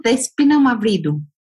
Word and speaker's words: Despina Mavridou: They can find me under the name also, Despina Mavridou.